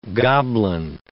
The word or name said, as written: goblin